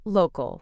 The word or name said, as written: local